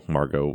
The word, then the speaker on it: Margo: Can you say how it's actually pronounced in Spanish? – Yeah, it's Margo.